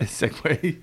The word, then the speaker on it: Segway: Is it Sieg or Segway? – Segway. – Segway or Sieg? – It's funny that I thought about that yesterday. – But how to say it? – Well, because I remember thinking, I used to say Sieg all the time. And I was like, man, I'm such an idiot. It's definitely Segway.